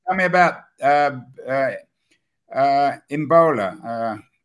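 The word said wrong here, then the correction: Imbolo Mbue: Imbolo Mbui is how you say her name.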